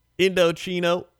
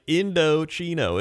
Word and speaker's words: Indochino: Now, Indochino, that's right, Indochino. It's pronounced Indo, like you're in dough, like dough. Chino, Indochino.